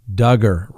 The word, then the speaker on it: Duggar: Now, let's talk about, that's right, Josh Duggar, rhymes with hugger.